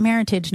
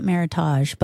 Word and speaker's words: meritage: They use the word meritage, which looks like heritage, but with an M. And they pronounce it meritage, not meritage. But that's a word that was made up and used to replace because they couldn't say Bordeaux on their label.